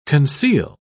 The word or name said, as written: conceal